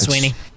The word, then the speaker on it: Swinney: He's reading it the way it's written, but it is Sweeney.